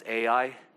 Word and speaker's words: Ai: I've been told by Hebrew or Old Testament scholars that it's pronounced I. You would probably know it more as AI, right? After the fall of Jericho, they went to I or AI.